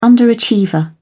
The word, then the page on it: underachiever: British English